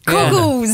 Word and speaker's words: Coggles: I mean, maybe just pronounce the G. – Coggles.